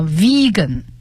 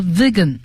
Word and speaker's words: vegan: 那么这个素食主义还有一个英文的词叫 vegan，它有三个读音啊，vegan 和 vegan 和 vegan，这是很严格的素食主义者的意思。